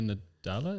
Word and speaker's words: Nadella: So not just our mate Phil here, but his big boss, Satya Nadella, is that how you pronounce it? – Sure.